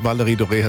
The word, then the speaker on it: Valerie Doré: Gut, also früher hat ja am Anfang alles gerätselt, wie wird die jetzt ausgesprochen, Valerie Doré. Aber nee, Valerie Doré hat es schon getroffen.